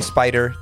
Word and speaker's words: Spider: I'm on Instagram at DJ Spider, DJ S-P-I-D-E-R.